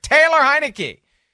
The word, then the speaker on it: Taylor Heinicke: Is it Tyler Hennig? Taylor. I'm so excited about this Cardinal situation. I can't get the name right. Taylor Heinicke.